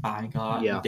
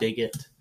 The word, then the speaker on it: bigot: No, I was trying to acknowledge me mispronouncing things like bygot, bigot.